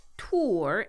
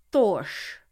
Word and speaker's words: Thor: In modern day, it is pronounced Tor in Norwegian and Swedish, and Tor in Danish. But in Old Norse, it's pronounced Thorsh. That SH sound is called a hard R, so Thorsh.